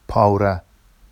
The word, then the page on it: pɑwra: Vafsi